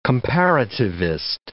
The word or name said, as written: comparativist